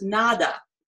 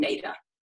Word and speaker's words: Neda: Well, my name actually means hope, and it's a Serbian name, and it's pronounced Nada, but it doesn't mean nothing. It means hope, and since we live in America where Spanish is spoken a lot as well, and nada means nothing. When we first moved to the United States, somebody that was close to our family member, she said, your name is going to be pronounced Neda. And since then, I've always been Neda.